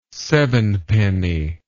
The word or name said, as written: sevenpenny